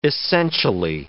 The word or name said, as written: essentially